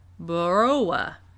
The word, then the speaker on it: Boorowa: B-O-O-R-O-W-A. Baroa.